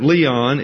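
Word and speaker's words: Leon (λίαν): Now, this is not Leon like a guy's name. That's L-E-O-N. This is L-I-A-N, pronounced Leon. Leon spelled backwards is Noel. But this is not Noel or Leon, this is Leon.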